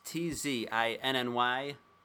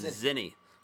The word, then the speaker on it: Tzinny: It is T-Z-I-N-N-Y, but the T is silent. – So it's Zinny.